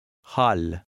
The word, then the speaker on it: hal: The long A also affects the sound of the letter L. Listen and repeat. – Hal.